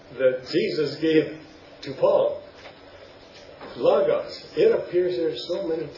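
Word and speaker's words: Logos (λόγος): It is not Logos, folks, because it's spelled Lambda Omicron, and the Omicron has the sound of Ah, Logos, Logos.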